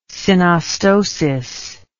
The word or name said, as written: synostosis